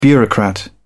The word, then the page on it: bureaucrat: British English